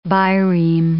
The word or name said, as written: bireme